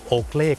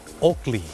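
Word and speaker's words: Oakley: คนไทยออกเสียงว่าโอเกลแต่เจ้าของภาษาไม่ได้ออกเสียงว่าโอเกลครับเขาออกเสียงว่าโอกลีโอกลี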